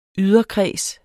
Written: yderkreds

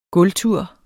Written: gulvtur

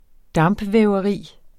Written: dampvæveri

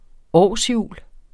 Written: årshjul

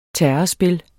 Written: terrespil

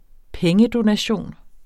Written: pengedonation

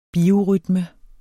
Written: biorytme

